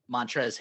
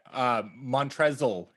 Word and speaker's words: Montrezl: Montrezl Harrell. Don't forget that silent L in there.